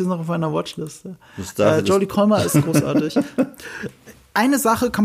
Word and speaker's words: Jodie Comer: Habe ich ihren Namen richtig gesagt? Ich guck gerade. – Jodie Comer, ja.